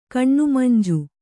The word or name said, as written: ಕಣ್ಣುಮಂಜು